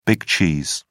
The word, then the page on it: big cheese: British English